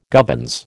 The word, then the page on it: gubbins: American English